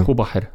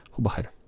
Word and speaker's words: Hubacher: Hubacher, tak mi się wydaje. Hubacher, tak z niemieckiego.